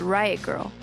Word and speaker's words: riot grrrl: The movement came to be called Riot Girl. That's girl spelled with no I and three R's. It's meant to be pronounced with a sort of growl.